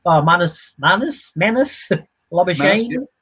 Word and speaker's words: Labuschagne: Manus Labuschagne.